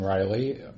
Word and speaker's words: Reilly: Yes, it's Kevin Riley, R-E-I-L-L-Y.